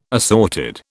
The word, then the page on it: assorted: British English